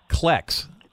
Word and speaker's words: klek: Well, one thing that you discovered, which the article is about kleks. Am I pronouncing that correctly? A klek? – Yep, exactly.